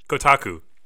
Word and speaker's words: Kotaku: I should know this because I took Japanese, but Kotaku is how it's pronounced,